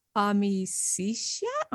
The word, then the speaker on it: Amicitia: Amicitia is friendship, or amicitia in ecclesiastical pronunciation.